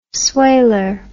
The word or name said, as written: swailer